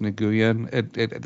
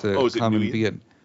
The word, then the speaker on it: Nguyen: I was just going to throw in there really quick that actress's last name is not pronounced Nguyen. That's how you would think you might do it, but it's Nguyen. It's a common Vietnamese last name.